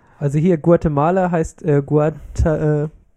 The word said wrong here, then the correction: Guatemalteken: Nein, ich kann es nicht mehr aussprechen. Guatemalteken. – Ach so. – Malteken. – Malteken. – Ja, okay. Guatemalteken.